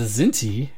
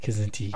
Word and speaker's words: Kzinti: Did we decide it was Kzinti or Zinti? – I think it's pronounced both ways. – Zinti? – I've heard it both ways. – Okay. Zinti is dangerously close to Zindi, so maybe I'll pronounce the K.